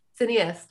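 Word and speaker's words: Cinéaste: how do you say it? Cineast, I think. – Cineast, yes. – Yeah, I think so.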